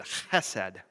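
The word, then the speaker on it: chesed (חסד): you can't help but try really hard to pronounce both those letters, and that's exactly how it should be pronounced. It's like it almost sounds like you're choking on the word chesed.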